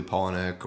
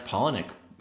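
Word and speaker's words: Palahniuk: It's very, if you like Bret Easton Ellis or early Polonic or, Amy Tremple, I believe. I'm sorry, I can't remember. Last names are not good with me. – That's okay. Well, hey, you pronounce Polonic fantastic.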